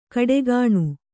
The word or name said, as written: ಘಟಾಗ್ನಿ